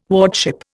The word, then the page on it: wardship: Indian